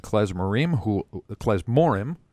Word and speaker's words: Klezmorim: You're listening to Drew Howard and David Klein talking about their band, Heartland Klezmorim, I beg your pardon. – Thank you very much. Thank you. – Accent on the right syllable there.